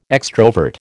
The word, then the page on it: extrovert: American English